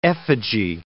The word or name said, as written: effigy